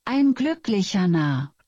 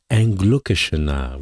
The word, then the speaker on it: Ein glücklicher Narr: Ça se prononce comment? On va demander à madame de translatron Google. – Ein glücklicher Narr. – Ein glücklicher Narr, voilà.